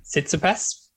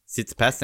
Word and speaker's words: Tsitsipas: Tsitsipas. – Tsitsipas, thank you.